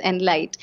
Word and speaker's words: Enlite: Yeah, it's E-N-L-I-T-E. So yeah, it's Enlight.